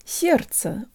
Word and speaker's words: сердце: guys, today you'll learn how to pronounce the word сердце, or heart. The D in this word disappears into the ц, which makes the second syllable super easy, and the second е sounds like a schwa.